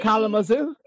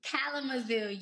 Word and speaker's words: Kalamazoo: You said Kalamazoo? – Kalamazoo, yes. – Spell it. – K-A-L-A-M-A-Z-O-O.